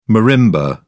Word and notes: marimba: British English

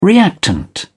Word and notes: reactant: British English

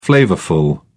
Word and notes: flavourful: British English

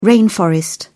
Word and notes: rainforest: British English

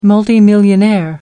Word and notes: multimillionaire: American English

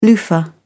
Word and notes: loofah: British English

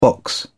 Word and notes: box: British English

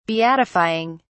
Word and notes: beatifying: British English

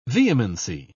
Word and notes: vehemency: British English

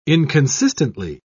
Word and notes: inconsistently: British English

